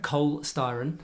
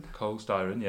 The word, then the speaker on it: Cole Styron: but I would like to shout out Cole Starr and Pete Austin as two people who took me under their wing very much. – Should I say his names again? – Cole Styron. – Cole Styron, yeah.